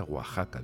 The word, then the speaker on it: Oaxaca: No, no, se pronuncia Oaxaca, digo, pues ya no se me olvida.